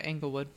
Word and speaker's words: Englewood: how do we say it? Englewood? – Englewood? Yeah, Englewood. – Englewood? With an E.